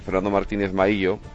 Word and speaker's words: Fernando Martínez Maíllo: Fernando Martínez Maíllo, hoy lo he dicho bien, fuera el sucesor de Juan Vicente Herrera en Castilla y León.